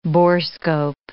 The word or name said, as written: borescope